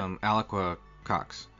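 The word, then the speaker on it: Alaqua Cox: Alquia Cox? Is that how you say it? – Alquia Cox. I looked it up just to make sure. I didn't want to butcher it, because it looks like it could be Alaqua or Alaqua, but yeah, she was great.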